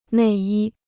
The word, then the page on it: 内衣: nèi yī